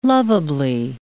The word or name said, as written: lovably